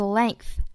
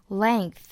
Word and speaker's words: length: 最后这个单词 length 稍微有一点不好发，我们看这个单词里面呢有一个 g，那么它应该发音成一个 g g 的发音。那我们看到音标里面呢，这个 ng 是发后鼻音 ng 的发音的。那么如果你把这个 g 发出来了，其实这个后鼻音就出来了。 length, length, length, length，这样去发。